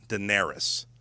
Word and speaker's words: Daenerys: Daenerys.